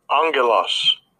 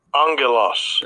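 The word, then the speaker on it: angelos (ἄγγελος): Strong's G32, angelos, angelos. – That's how you say it in the Greek.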